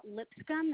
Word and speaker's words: Lipscomb: My email address is k.n, as in Nicole, dot Lipscomb. That's LIPSCOMB, as in like a hair comb, 88 at Gmail.